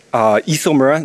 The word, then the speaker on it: Isomura: Like I said, my name is Toshiya Isomura. It's pronounced with an E, even though it's an I.